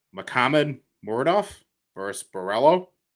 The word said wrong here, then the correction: Makhmud: Was I close at all at that? – Makhmud Muradov versus, I don't know how to pronounce that first name.